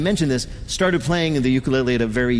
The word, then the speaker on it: ukulele: Peter's first question to Taimane: How the hell do you pronounce ukulele? – Ukulele. – Ukulele. – Uku. – Uku. – Lele. – Lele. – You got it.